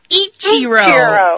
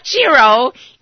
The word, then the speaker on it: Ichiro: Now, you got to say it right now. Ichiro. – Ichiro. – No, not Chiro. Ichiro.